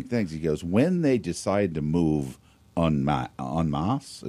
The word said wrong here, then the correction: en masse: is that how you say it? En masse. – Mm-hmm. En masse.